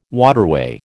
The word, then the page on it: waterway: American English